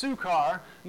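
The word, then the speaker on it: Sychar: So he came to a town in Samaria called," it's pronounced Sukkar, "near a plot of ground Jacob had given to his son Joseph.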